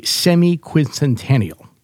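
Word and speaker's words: semiquincentennial: Let me see if I say this correctly. The semi-quincentennial. Okay, that's excellent.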